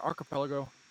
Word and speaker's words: archipelago: Is that archipelago? – Yeah. Yeah, you're right.